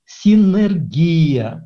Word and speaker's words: синергия: Ну и тут же попутно, это я уже из нашего чата, синергия, правильное ударение на «и».